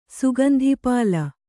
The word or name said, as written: ಸುಗಂಧಪಾಲ